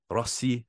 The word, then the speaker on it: Rossi: So breakout player, Marco Rossi, I think is how you say it. He said, if you can't roll your R's, to just say it like Rosie, but whatever.